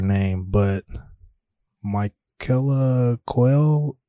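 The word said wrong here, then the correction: Coel: Michaela Cole.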